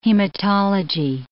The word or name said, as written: hematology